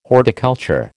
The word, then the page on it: horticulture: American English